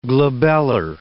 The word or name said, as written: glabellar